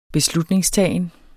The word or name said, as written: beslutningstagen